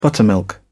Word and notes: buttermilk: British English

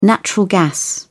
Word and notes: natural gas: British English

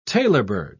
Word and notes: tailorbird: British English

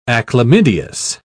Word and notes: achlamydeous: British English

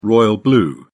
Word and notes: royal blue: British English

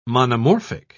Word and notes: monomorphic: British English

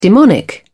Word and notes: demonic: British English

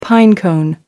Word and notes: pinecone: British English

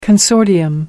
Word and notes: consortium: American English

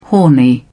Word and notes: horny: British English

